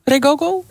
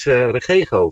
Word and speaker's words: Regogo: Zeg ik het goed eigenlijk? Regogo? – Nee, het is Regogo.